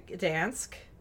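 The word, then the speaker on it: Gdańsk: The next day, they left Warsaw and they went to Gdansk.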